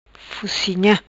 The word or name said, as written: Fucinha